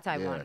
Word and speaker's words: Taiwan: Taiwan. – You said Taiwan, yeah.